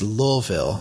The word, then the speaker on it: Louisville: But I have some cousins that live there, and they said, no, that you just sound stupid when you say that. It's Louisville. Hmm. Which sounds stupid to me, but I'll accept it. You know, Louisville, that's how they say it.